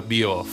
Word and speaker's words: Bierhoff: It was not Oliver Bierhoff.